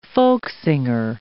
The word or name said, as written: folksinger